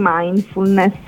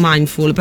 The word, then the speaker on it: mindfulness: Assolutamente, parliamo di mindfulness. – Ah, vedi, prima ho sbagliato, ho dimenticato l'ultimo pezzo, ho detto mindful,